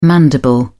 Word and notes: mandible: British English

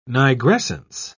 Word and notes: nigrescence: British English

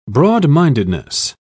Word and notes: broad-mindedness: British English

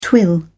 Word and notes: twill: British English

